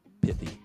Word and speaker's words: pithy: It's lush, tropical, and I think I'm pronouncing this right by saying pithy. P-I-T-H-Y, pithy. – Hey, sounds good to me. – I'm not going to make it the long I, pithy.